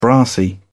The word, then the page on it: brassy: British English